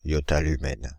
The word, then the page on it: yottalumen: /jɔ.ta.ly.mɛn/; France (Île-de-France)